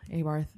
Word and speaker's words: Abarth: Abarth. There's which sounds like a Game of Thrones character name, if you ask me. – I think in Italy they actually pronounce it Abart.